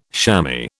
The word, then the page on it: shammy: British English